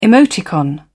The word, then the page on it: emoticon: British English